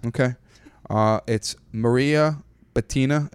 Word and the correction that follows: Бутина: I hope I'm pronouncing it correctly. Maria, welcome to the show. – Привет, здрасте. Меня зовут Мария Бутина. – Мария Бутина. Бутина. Yeah.